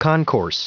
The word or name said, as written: concourse